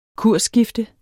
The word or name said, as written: kursskifte